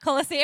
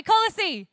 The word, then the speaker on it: Colossae: Everyone say Colossae, did I say that right? Colossae, everyone say Colossae, the church in Colossae.